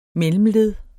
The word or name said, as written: MELLEMLED